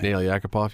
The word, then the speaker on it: Nail Yakupov: Um, is it Yakupov Nail? How do you say his name? Nail Yakupov, yep.